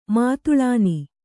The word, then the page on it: ಮಾತುಳಾನಿ: kannada